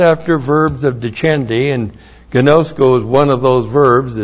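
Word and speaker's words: gnosko (γινώσκω): G-I-N long O-S-K long O. Gnosko.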